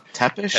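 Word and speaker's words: Țepeș: Tepish? – Tepish. – You're right, I'm sorry. It's pronounced... – You're right. – It's pronounced like TS, it's tepish.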